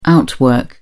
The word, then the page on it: outwork: British English